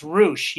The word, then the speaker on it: Roche: So it's Roche. Yeah, Roche. She was on her show. She just does, like, think of the word douche, but just put an R in front.